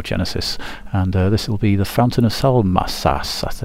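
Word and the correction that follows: Salmacis: Salmasis, I think I pronounced that correctly, and that's from the Nursery Crimes album, so see you after the music.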